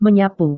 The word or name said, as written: menyapu